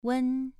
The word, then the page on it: wen: 2